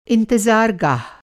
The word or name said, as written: इंतज़ार-गाह